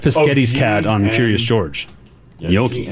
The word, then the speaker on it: gnocchi: In Italy, it's gnocchi. – Oh yeah, that one trips me up. Gnocchi? – It's spelled G-N-O-C-C-H-I.